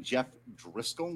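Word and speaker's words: Jeff Driskel: Hey now, hey, the Broncos are you putting your dollar on Jeff Driscoll? Did I pronounce that? – Yes, you did.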